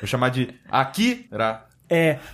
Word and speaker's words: Akira: Com licença, o idioma japonês ele é bem silabado e não tem ênfase em nenhuma sílaba como no português. Então você tem que falar Akira.